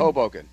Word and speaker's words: Hoboken: Hoboken? – Hoboken.